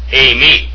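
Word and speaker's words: a-me (εἰμί): the epsilon iota is pronounced a, as in ace. It has a smooth breathing comma over the second vowel, and then it has an accent over the iota, and it's pronounced a-me, and it's very important to do that to yourselves, in your minds, at least in your minds or out loud.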